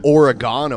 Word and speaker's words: Oregon: That's actually pronounced Oregon, Ohio.